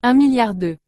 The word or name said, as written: 1000000002